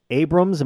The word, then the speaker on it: Abrams: Abrams, am I saying that right? – That is correct.